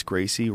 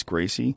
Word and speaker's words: Gracie: Many people called Royce Gracie, Royce Gracie? – Yeah. – Because they were, you know, unaware that you have to pronounce the R like an H when you speak in Portuguese.